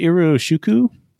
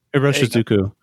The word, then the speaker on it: Iroshizuku: And I've been writing, been using an Iru, how do you say it? Iroshuku? – Iroshizuku.